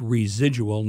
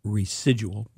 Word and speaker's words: residual: And that's pronounced residual, not residual. But anyway. – There's no Z, but you pronounce it like it's a Z.